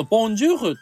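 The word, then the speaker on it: bonjour: だけど、このボンジューフっていう単語を見ると、まず、あの、最後のルってみんなが発音してるところに、まず母音がないのね。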